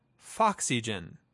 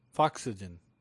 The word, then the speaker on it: Foxygen: But it's pronounced as if it were the word oxygen with an F in front of it. Foxygen. – Foxygen.